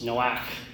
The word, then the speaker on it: Noach: So the name Noah in Hebrew is actually pronounced Noach, and it means rest or comfort.